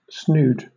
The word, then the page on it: snood: British English